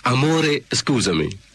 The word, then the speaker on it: Amore Scusami: Yes, as you say, actually, the right pronunciation is Amore Scusami.